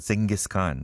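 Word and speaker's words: Dschinghis Khan: Well, I guess it is pronounced Genghis Khan, right? But it is spelled in a German way.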